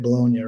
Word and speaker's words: Bologna: So I have, I think, 4 days off after Bologna. Is that how you say it? Bologna, right? – Correct.